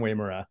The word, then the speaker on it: Uyemura: So for your sake and for the audience sake, my name is Dan Uyemura.